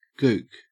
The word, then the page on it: gook: Australian English